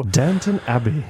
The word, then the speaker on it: Downton Abbey: Downton Abbey.